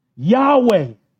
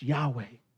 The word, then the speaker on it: Yahweh: So we actually don't know how to pronounce it. We're guessing. We've lost the vowels to history. But we think it's pronounced Yahweh.